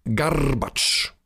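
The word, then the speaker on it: Garbacz: Und Jakub Garbatsch, das gerollte R, darf man nicht vergessen.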